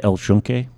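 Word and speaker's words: El Yunque: If you go into El Chunque, I did pronounce that right, by the way.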